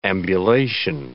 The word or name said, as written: ambulation